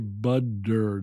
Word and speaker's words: butter: It's the same as when we say butter. It's spelled B-U-T-T-E-R, butter. But we say budder, budder, a D sound.